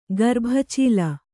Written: ಗರ್ಭಚೀಲ

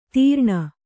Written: ತೀರ್ಣ

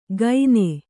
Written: ಗೈನೆ

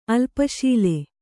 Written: ಅಲ್ಪಶೀಲ

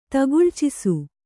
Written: ತಗುಳ್ಚಿಸು

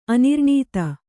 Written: ಅನುರಣಿತ

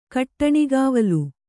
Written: ಕಟ್ಟಣಿಗಾವಲು

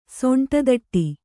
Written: ಸೊಂಟದಟ್ಟಿ

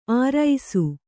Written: ಆರೈಸು

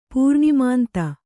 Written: ಪೂರ್ಣಿಮಾಂತ

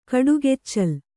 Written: ಕೊಡಗೆಚ್ಚಲ್

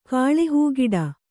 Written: ಕಾಳೆಹೂಗಿಡ

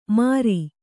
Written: ಮರಿ

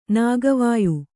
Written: ನಾಗವಾಯು

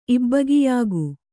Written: ಇಬ್ಬಗೆಯಾಗು

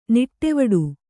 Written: ನಿಟ್ಟುವಡು

